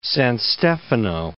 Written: San Stefano

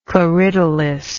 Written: corydalis

